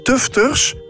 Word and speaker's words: tufters: Een monumentale touwinstallatie waarbij touwkunstenaars, breiers en tufters, zeg ik het goed? Ja, elke dag nieuwe lagen toevoegen.